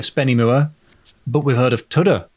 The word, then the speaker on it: Tudhoe: Or to pronounce that correctly, come with me to Tudhoe near Spennymoor.